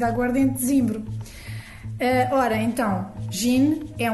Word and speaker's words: gin: termina em N. Então, isto significa que os portugueses devem dizer gin ou gin, quando muito, não é? Gin, como se tivesse quase um Ezinho no fim, mas que não está na grafia.